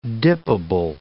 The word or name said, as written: dippable